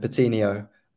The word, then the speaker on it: Patino: Charlie Patino, is that right? Is he the next big thing at Arsenal, and might we see him? Yeah, we call him Patino. Um, no, Charlie Patino.